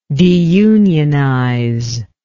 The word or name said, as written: deunionize